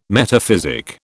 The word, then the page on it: metaphysic: British English